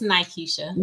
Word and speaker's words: Nykisha: Is it Nykisha or Nakisha? – It's Nykisha.